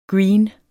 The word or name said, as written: green